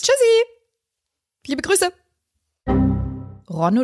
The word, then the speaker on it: Le Rhône: How to pronounce. Le Rhône. – Le Rhône.